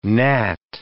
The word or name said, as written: gnat